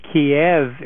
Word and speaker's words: Kiev: Well, from what my understanding is that Kiev, K-Y-I-V, is sort of the Ukrainian pronunciation, and Kiev is K-I-E-V, is the Russian pronunciation. The same city, just pronounced different ways in two slightly different languages.